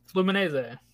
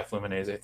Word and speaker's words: Fluminense: They go get Caligari, who was a right back that played at I'm going to butcher this. I think it's Fluminense, or some. It was one of the big clubs in Brazil. I forget what they're called. – Fluminense. – Yeah, Fluminense. Thank you.